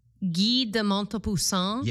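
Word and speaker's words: Guy de Maupassant: Guy de Montepassant.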